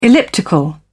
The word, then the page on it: elliptical: British English